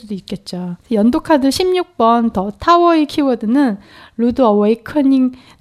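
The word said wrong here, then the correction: awakening: 다시 이거 발음 다시 할게. – 편집은 없습니다. – 아야! – 말씀하세요. – 루드 어웨이크닝입니다. 이게 RUD가 거친 네. 어웨이크닝이 그 깨닫는다.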